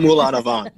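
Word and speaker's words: Moulin-à-Vent: Gee, we're talking about Moulin Vin. How do I say that? – Not Moulin Avant. – Moulin Avant. – Moulin Avant. Yes, Moulin Avant.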